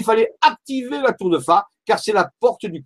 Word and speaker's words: Fa: Et un de ces points, c'est la tour de Fa. Alors la tour de Fa, c'est un petit village, ça s'écrit F-A, comme la note de musique.